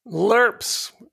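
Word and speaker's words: LIRPs: I was just listening to a book about reducing taxes in retirement, and the author was touting the benefits of, bro, is this going to be pronounced LERPS? – LERPS. Not to be confused with LARPing, it's LERPing.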